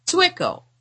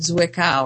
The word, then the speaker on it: Zwickau: the way to pronounce it is Tick. Oh, now I don't have it in front of me. Here it is. Twicko. I was calling it Zwickau or something like that, or Twicko.